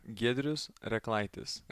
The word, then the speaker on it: Giedrius Rekleitis: Giedrius Rekleitis.